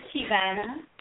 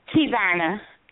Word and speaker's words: Tevona: Hi, my name is Tivana. – Your name is what? – Tivana. T-E-V-O-N-A. – O-N-A. I like that. Hello, Tivana. – Hi. – Am I saying that right, Tivana? – Yes.